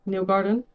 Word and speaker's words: Newgarden: Joseph Newgarth. – Newgarden. Yeah. Close.